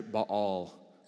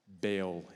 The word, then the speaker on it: Baal: It's actually Baal, but we're going to say Baal in the more familiar pronunciation.